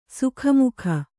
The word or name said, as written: ಸಾಕುಮಗ